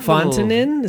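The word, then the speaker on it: Fonteinen: Fontenin? Is that how you say it? – Yeah, Three Fontenin, yeah.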